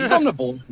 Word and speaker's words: Somnambulist: Yeah, I think Somnambulist is actually how you pronounce it. Somnambulist is actually how you pronounce it. I was pronouncing it wrong all along.